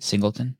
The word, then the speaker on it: Singleton: Did I say your last name right? Singleton? – Yeah, that's right.